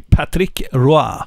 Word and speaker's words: Patrick Roy: Patrick Roy. – Ja, bra uttal också. Kanada-uttal.